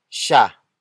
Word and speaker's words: siya: The next word is sha. Sha. Sha. Sha is spelled as S-I-Y-A.